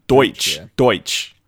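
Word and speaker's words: Deutch: Zoe Dutch or whatever her name is. – Zoe Deutsch. Deutsch. I believe that's how it's pronounced.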